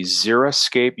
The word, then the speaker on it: xeriscape: I have to tell you, probably one of my bugaboos or pet peeves is the xeriscape. You pronounced it correctly.